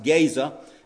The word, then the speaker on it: Gaza: I know today it's usually pronounced Gaza, but in the Hebrew it's pronounced Gaza.